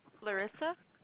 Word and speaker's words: Larissa: Larissa.